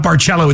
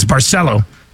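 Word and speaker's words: Barceló: Daniel, who's at the Nasty Bash, says we were saying it wrong. It's not Barcello, it's Barcelo.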